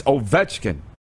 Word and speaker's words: Ovechkin: Right. It's Ovechkin. It's the middle syllable.